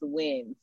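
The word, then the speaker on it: Nguyen: Usually, that last name is usually pronounced Win.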